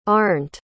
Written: Arnt

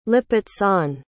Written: lipizzan

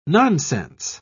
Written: nonsense

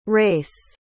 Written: rayce

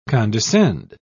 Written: condescend

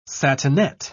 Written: satinet

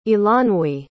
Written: eilonwy

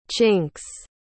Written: chinks

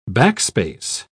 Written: backspace

